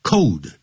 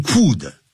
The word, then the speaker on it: could: 常常会这样问到，这我就不再说了。安排会面，number eight， perhaps we could 那个 could 不要念成 cold，更不要念 could 啊，叫 could，